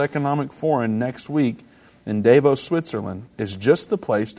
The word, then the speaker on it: Davos: Klaus Schwab pledges the world can find salvation at Davos 2022. I don't even know if that's how you say it, but it's in Switzerland.